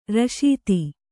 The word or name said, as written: ರಶೀತಿ